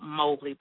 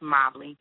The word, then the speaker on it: Mobley: That's J-A-N as in Nancy, double E as in egg. Last name is spelled Mobley, but pronounced Mobley. M-O-B-L-E-Y, if anything else, you can Google me.